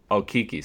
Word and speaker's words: Okeke: They added Al-Farouq Aminu and Josh McGetty, and of course they drafted Chuma Okeke, I believe is how you pronounce it. – Okeke, sorry.